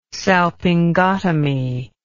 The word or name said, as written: salpingotomy